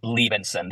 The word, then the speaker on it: Liebensohn: It's pronounced Liebensohn. The H is silent.